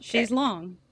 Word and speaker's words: chaise longue: So is it lounge? But that's how you say it in French? – How you say it in French. Okay. Chaise longue.